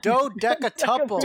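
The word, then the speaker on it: dodecatuple: Dodeca tuple. – Dodeca loople. – Dodeca couple his fee. – Oh my god, you still said it wrong. Hobgoblin shows up and he's hoping to dodeca tuple his fee.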